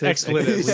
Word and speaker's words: expletives: Also, I love that he teases Adrian for his pronunciation of expletive. – Expletives. – That's what it's expletives. Expletives.